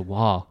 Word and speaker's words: Roy: I was like, hey, what's up with this Roy guy? He's like, who? I said, Roy, the guy that we traded Howla for. And he's like, oh, his name's Wah. Yeah, that makes sense because it's spelled R-O-Y.